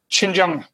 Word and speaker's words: Xinjiang: Xinjiang.